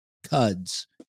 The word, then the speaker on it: Cuds: And by the way, Nikki Cuds, we were debating Cuds or Cuds, but we found out Cuds, Cuds for the win, rhymes with Cubs.